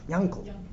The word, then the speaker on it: Yankel: I once had a long discussion with a philologist about it, and Lamaisa, whatever the makar of it is, the Litvish Russian pronunciation of Ayin is sometimes a Nun. Like, how do you say the colloquial Yaakov? Yankel. Where does Yankel come from?